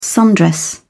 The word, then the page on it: sundress: British English